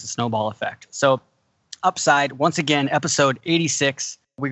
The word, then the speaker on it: UPSYD: And I've kind of reversed the order and created an acronym, UPSIDE, which is UPSYD. And it looks like UPSID, but it's upside. The reason why it's pronounced that way is because once you truly understand this,